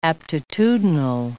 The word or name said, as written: aptitudinal